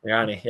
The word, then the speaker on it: Sirianni: I can never pronounce his name, but the new coach for the East, Nick Sirianni? – Sirianni, yeah, you got it.